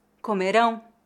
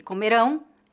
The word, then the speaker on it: comerão: A única diferença de pronúncia entre as palavras comeram e comerão é a sílaba tônica.